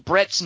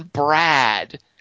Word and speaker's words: Brett: Jules, intentionally or not, mispronounces Brett's name while saying the line and actually says, check out the big brain on Brad. – Right. – The humanity. But his name is really Brett.